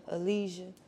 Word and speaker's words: Aleza: Okay, so phonetically, like A-L-E-Z-A, phonetically, the Z-A is like Je, Aleza. So, like A-L-E-Z-A, Aleza, because it's like simple.